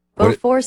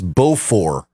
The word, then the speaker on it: Beaufort: The T is silent. – Beaufort scale. – Oh, okay, because it's British. – Right. – Invented by a Royal British Navy officer, Sir Francis Beaufort. – Beaufort.